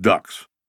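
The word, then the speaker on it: DUKW: Dad served in the South Pacific driving DUKWs, amphibious vehicles the troops pronounced ducks.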